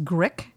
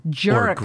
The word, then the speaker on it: GRC: I would have said GRC and then I just said Grick, which it's Jerk.